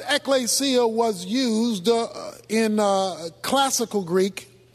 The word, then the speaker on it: ekklesia: The word church is taken from a Greek word pronounced ekklesia. And the Greek term ekklesia means called out ones or an assembly. But in New Testament theology, in fact, in New Testament usage, the word ekklesia,